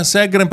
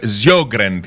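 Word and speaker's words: Sjögren: Α, Σέγκρεν προφέρεται. Την ήξερα. Γιώργεν τη λέγαμε εμείς όταν κάναμε. – Γιώργεν. – Έτσι τη λέγαμε, αλλά το σωστή προφορά είναι Σέγκρεν, όπως το βλέπεις εσύ. Εμείς λέγαμε Σιόγκρεν τη λέγαμε.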